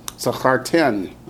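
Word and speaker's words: Zachartem (זכרתם): Zachartem. – Uh-huh, accents on the ten.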